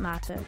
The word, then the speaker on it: mate: Of course, given the German pronunciation, here we call it mate.